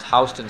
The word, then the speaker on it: Houston: So just a little bit north of the Bowery is this street running east and west, which is pronounced Houston Street.